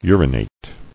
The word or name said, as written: Urinator